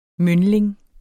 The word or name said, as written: myndling